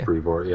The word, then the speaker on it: Brevoort: And editor Tom Brevoort, is that how you pronounce it? – I think it's Brevoort, yeah.